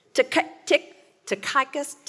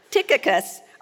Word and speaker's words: Tychicus: Verse 7, as to all my affairs, Tychicus, all my life I've called him Tychicus. And I found out this week I've been pronouncing his name wrong all this time. Tychicus, our beloved brother and faithful servant and fellow bondservant in the Lord, will bring you information.